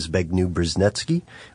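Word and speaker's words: Zbigniew Brzezinski: You can also read a book called The Grand Chessboard by a guy named Zbigniew Brzeznetsky, which I cannot believe I pronounced correctly.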